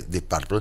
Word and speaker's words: Deep Purple: Sí, lo dije bien y no me equivoqué, Deep Purple.